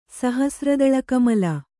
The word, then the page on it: ಸಹಸ್ರದಳಕಮಲ: sahasra daḷa kamala